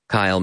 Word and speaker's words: Kyle: I'm Kyle Miller is the name on Instagram. E-M-A. I'm Kyle Miller. K-Y-L-E-M-I-L-L-A-R.